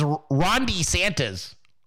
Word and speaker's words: DeSantis: The way he used to say it was Rondi Santas. Rondi. He sounded like Rondi. Rondi Santas.